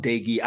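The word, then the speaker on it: Doege: and you're putting Jarrett Doge in a system where he already has familiarity. – Absolutely. So Jarrett Dagey, and it's pronounced Dagey.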